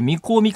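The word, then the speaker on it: 味香: というところの、味に香ると書いて、みこうみかんっていうんですか。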